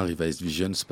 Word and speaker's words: Revised Visions: Est-ce que je le prononce bien, Revised Visions Parce que je suis pas sûr. – C'est parfait.